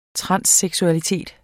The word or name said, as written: transseksualitet